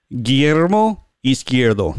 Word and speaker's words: Guillermo Izquierdo: Guillermo Izquierdo.